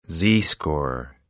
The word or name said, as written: z-score